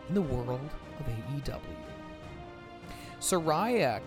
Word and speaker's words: Soraya: She said, 'My name is pronounced Soraya.'